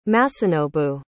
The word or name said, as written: Masanobu